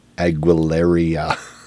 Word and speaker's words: Aguilera: And a large, full-sized like stand-up of Mariah Carey and Christina Aguilera side-by-side. Rhymes with malaria. – Say that again. – Aguilera.